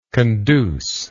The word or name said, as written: conducible